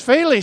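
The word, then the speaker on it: Féile: How do you say that? The field? The field? The field. That's a GAA thing. The Faley. She don't know. You'll be with us in just a minute. St. Giles Youth GAA is proud to be sending its first team from Chicago to participate in the Faley tournament. Pronounced Faley. She's right, Faley.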